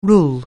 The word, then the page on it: rule: anglais